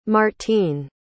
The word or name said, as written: martine